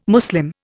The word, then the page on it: Muslim: Indian